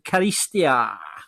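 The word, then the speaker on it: Charisteas: Dubious pronunciation, Chris, but I'm going to give it to you. It's Angelos Karistia.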